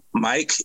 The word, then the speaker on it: Mike: And just for those who may be confused, I spell my name Mike, M I K E, the letter G, the letter Z.